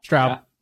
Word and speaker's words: Straub: Straub.